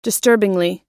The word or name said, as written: disturbingly